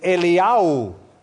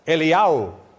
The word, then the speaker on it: Eliyahu: The Hebrew pronunciation of Elijah is Eliyahu. Eliyahu. And the pronunciation of Elisha is Elisha. So you don't get Eliyahu and Elisha confused.